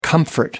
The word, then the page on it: comfort: American English